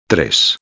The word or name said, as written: 3